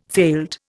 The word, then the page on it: failed: Indian